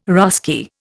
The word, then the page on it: Russki: Indian